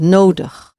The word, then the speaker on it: nodig: N-O-D-I-G. Nodig. The I-G bit at the end of words gets pronounced like a again, like this neutral sound, nodig.